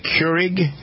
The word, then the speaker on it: Keurig: I have a Keurig.